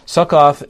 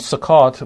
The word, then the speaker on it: Succoth: Succoth is how you'd pronounce it, I believe, in Hebrew. This town, Succoth, was right on the eastern side of the Jordan River, not too far away from the Jordan River.